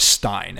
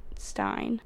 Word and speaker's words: Berenstain: Berenstain. – Yeah, I've never heard it pronounced any other way than Berenstain Bears. – And if it's pronounced that way, then it would almost have to be E-I-N.